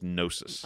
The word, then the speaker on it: gnosis: Do you say it gnosis? I always want to say gnosis, or is it just gnosis? – I think it's... – The C is silent, or the G is silent.